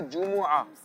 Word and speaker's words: جمعہ: اچھا, آپ کی جو ایک مسٹیک تھی کنٹینیو چلتی رہی, وہ تھا جمعہ. اصل جو تلفظ ہے وہ ہے جمعہ. قرآن کریم میں بھی جمعہ کہا گیا, لیکن ہم جمعے کے فضائل, آپ نے یہ بھی کہا کہ جمعے کے دن یہ کرنا چاہیے جمعے کے دن.